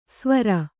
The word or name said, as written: ಸ್ವರ